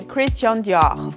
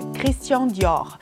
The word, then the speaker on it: Christian Dior: 它的正确读音是 Christian Dior，法语是 Christian Dior。